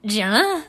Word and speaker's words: Jenna: And I can't even say my name properly. I'm like, I'm Jenna. I say it so weird. Like, what happens to the J anyway?